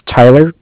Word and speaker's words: Tyler: Okay, my name is Tyler, T-Y-L-E-R, and I am calling with Cardiff. It's spelled C-A-R-D-I-F-F, as in Frank Frank.